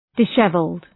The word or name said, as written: dishevelled